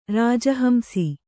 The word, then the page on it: ರಾಜಹಂಸಿ: rāja hamsi